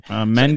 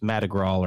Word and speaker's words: Madrigal: Mendick? – Their lineup is Mendick? Is it Mendick? – No, no, it's Madrigal or Magadrawler.